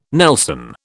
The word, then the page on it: nelson: British English